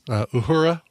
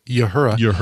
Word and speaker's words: Uhura: We mentioned earlier that Nichelle Nichols, Uhura, or as some people pronounce it, Uhura, passed away this last weekend.